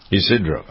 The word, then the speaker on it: Ysidro: That's how you, is it Ysidro? How do you pronounce that? – Ysidro.